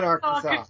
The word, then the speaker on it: Arkansas: I used to live in Arkansas.